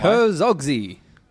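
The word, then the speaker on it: Herzog Zwei: Herzogzy. – So, it's H-E-R-Z-O-G, new word, Z-W-E-I. Herzog's Y. – Yeah, that's right.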